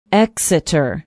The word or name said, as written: Exeter